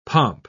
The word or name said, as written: pomp